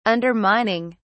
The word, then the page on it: undermining: British English